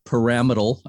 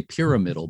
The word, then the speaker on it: pyramidal: And one of the interesting things about Fort Point Light Station is that it still has one of the few 19th century pyramidal, I used to say pyramidal, but I believe the correct pronunciation is pyramidal, fog bell towers still standing.